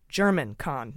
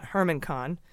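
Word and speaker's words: Germán Khan: It's been pointed out to me by someone in the know that it's pronounced German Kahn, not Herman Kahn.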